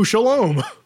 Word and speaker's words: Scialom: It's spelled like Skialom. It's like the Italian way of saying Shalom.